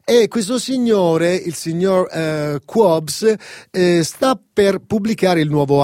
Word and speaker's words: Kwabs: Sì, questo signore si chiama Qobs, scritto K-W-A-B-S, la pronuncia esatta dovrebbe essere Qobs, un cantante favoloso che ha fatto varie esperienze.